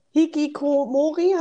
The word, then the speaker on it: Hikikomori: Ich mache nur nochmal, also wir haben über Hikikomori, habe ich das richtig ausgesprochen? – Sehr Hikikomori schön, ja. gesprochen, ja.